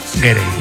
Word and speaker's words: Gere: estaba pensando yo que si te apellidas Ger, bueno, ¿cómo se dice Ger o Gerde? Gere.